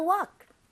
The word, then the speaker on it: walk: Let's take a walk. – Take a 10-minute walk. – Take a 10-minute walk. – 자 a 그리고 walk. A walk. 예. 주의하셔야 돼요. – 네, 맞아요. – 아, 요거는 묵음이에요. Uh-huh. Walk입니다.